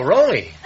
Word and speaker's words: Roly: R-O-L-Y. – Oh, Roly.